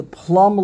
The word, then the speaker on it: plumb: B-O-M-B-E. So the B would be pronounced. – Would be pronounced, the bomb. Okay, the plumb line. Even though it's spelled with a B, you don't pronounce the B, the plumb line.